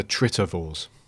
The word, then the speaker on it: Tritovores: the the Trivators. Am I saying that right? Yes, go with it. – Yeah, the, no, the the Tritovores. Oh, that's it.